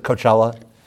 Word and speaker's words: Coachella: Is that how you pronounce it, Coachella? – Yes.